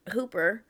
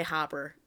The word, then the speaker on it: Hooper: In real life, Steven Spielberg and Hooper. – Well, you said the name right. I don't know why you're gonna. – Because I keep wanting to say Hopper.